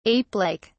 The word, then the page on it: apelike: British English